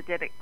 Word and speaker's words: Dybbuk: and I'm always saying this wrong, but the Dybbuk box. – That's right. Correct.